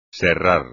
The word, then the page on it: serrar: espagnol